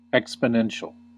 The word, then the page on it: exponential: American English